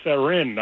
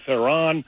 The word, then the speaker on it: Theron: It's Theron, not Theron.